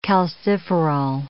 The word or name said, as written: calciferol